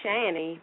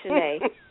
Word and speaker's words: Shanae: Will call me Shanny because of the way it's spelled, but it's Shanae. Yeah, my yeah. – last name is Dowdell, Shanae Green Dowdell, right.